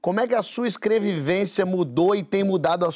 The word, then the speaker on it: escrevivência: Ela chama isso de escrevivência.